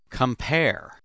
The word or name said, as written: compare